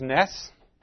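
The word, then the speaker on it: Nes (נס): This word is Nes.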